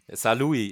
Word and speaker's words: Saarlouis: Saarlouis wird es, glaube ich, ausgesprochen, oder? Saarlouis, ja genau.